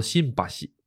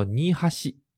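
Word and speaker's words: 新橋: 同样道理啊，新桥呢，呃，东京其实也有也有一个地方叫做新桥，但是它的发音呢跟这边不一样啊啊，东京那个新桥叫做新巴西，咱们我们玉电厂这边这个叫做尼哈西。啊，新巴西和尼哈西，它是两个发音，但是汉字写的是一样的，就日文汉字写的是一样的。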